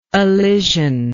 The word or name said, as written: allision